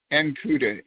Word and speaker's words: Ankuda: Ankuda, A-N-K-U-D-A. – Spell it again. – A-N-K-U-D-A.